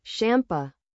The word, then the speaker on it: Sampa: Meet Sampa the Great, pronounced Shampa, born in Botswana and raised in Australia.